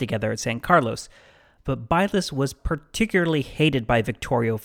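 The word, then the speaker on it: Bylas: or as we pronounce the settlement along US 70 named after him today, Bylus.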